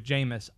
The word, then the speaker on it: Jameis: J-A-M-E-I-S, Jameis Winston. His name rhymes with famous. It's famous Jameis Winston.